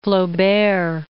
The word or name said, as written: Flaubert